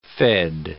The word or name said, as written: fed